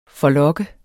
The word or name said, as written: forlokke